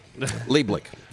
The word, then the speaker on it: Leiblet: It was it was two syllables. Leiblet.